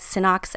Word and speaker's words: Synox: Am, am I pronouncing that right? Synox MD? – Yes. Yes. So, um, well, Synox MD is the key to Simply Venom.